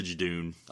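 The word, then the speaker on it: Judoon: The Jadoon. I always remember it because it rhymes with the moon. Jadoon on the moon.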